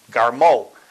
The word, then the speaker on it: Garmu: Now, the Garmu family, it's spelled G-A-R-M-U. Some spell it with an O instead of the U. And it's, some people pronounce it Garmu, some Garmo.